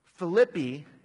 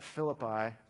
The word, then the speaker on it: Philippi: Philippi is the name of the city. We often call it Philippi, but I understand that the actual pronunciation, proper pronunciation, is Philippi.